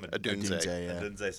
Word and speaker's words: Odunze: Odunze, sorry.